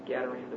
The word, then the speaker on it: Gadarene: Now, Mark chapter 5 is the, how do you say the, Gadarene? Gadazarene? Or Gadarene.